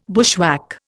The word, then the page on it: bushwhack: Indian